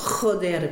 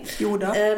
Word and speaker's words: Joder: Ja, apropos, ich habe ja das letzte Mal dieses Schimpfwort Joder benutzt. – Ja, und haben wir das falsch ausgesprochen? – Nee, nee. – Yoda. – Yoda. Yoda klingt irgendwie ganz seltsam. Joder.